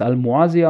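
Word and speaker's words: Al-Mawasi: Al-Mawazi, so schreibt man es, man spricht es Al-Muazi aus.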